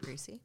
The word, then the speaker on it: Griesse: So the only other deputy that works with Sheriff Vinson, because this is such a small area, is Leroy Greasy, G-R-I-E-S-S-E. – I'd pronounce it Greasy.